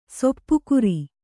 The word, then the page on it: ಸಾಪುಗಾರ: sāpugāra